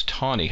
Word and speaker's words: Taney: Hey, before we leave Chief Justice Taney, when you read his name, it looks like Taney, T-A-N-E-Y. And in fact, I must admit that mentally I pronounced it Taney for many years, and yet consistently everyone will tell you, no, it's Tawny.